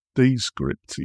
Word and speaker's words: Descript: So let's start off with number one, which is Decrypt. I think I'm pronouncing it. How would you pronounce it? – Descript, yes.